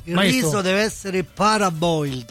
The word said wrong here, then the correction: parboiled: Scritto paraboiled, scrivo bene, mi Paraboiled, si legge però? Parboiled. Giustamente che in inglese.